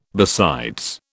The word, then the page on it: besides: British English